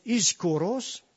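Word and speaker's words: ischoros (ἰσχυρός): Le mot fort que là, ça se prononce ischoros en grec.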